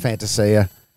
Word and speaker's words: Fantasia: Where he said, look, it's pronounced Fantasia. Does it bother you, though, that we say Fantasia? – I'd rather say this.